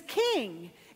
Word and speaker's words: Khing: The story is about a woodcarver, and the woodcarver's name is King, except you spell it K-H-I-N-G.